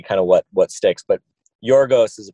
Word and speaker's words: Yorgos: my official pronunciation, I've had a couple Greeks comment complimenting us for calling him Yorgos and not Georgios.